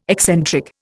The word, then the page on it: eccentric: Indian